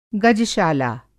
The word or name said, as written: गजशाला